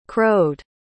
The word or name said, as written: crowed